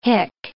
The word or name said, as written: hick